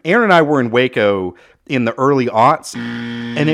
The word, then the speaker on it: Waco: It's pronounced Waco.